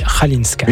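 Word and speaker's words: Chwalińska: Oui, Radinska, pour vous la faire croire, ça s'écrit C-H-W-A-L-I-N-S-K-A. Voilà, magnifique. – Ça se dit Halinska.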